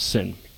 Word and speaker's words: Sin: And we're told that they're traveling out through the wilderness of sin. And mind you, it's not sin as in the word we know of sin in English. It just sounds like the word sin in their language.